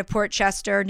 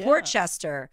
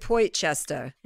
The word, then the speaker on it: Port Chester: Port Chester. Somebody said I have to pronounce it Poit Chester. – No. – I was like, I don't think I'm going to do that.